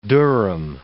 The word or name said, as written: Durham